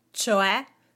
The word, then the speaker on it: cioè: Do you know how to use the word cioè in Italian? First things first, the pronunciation. There is an accent at the end, cioè, e, and an O in there as well. It's not c'è.